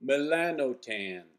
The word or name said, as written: melanotan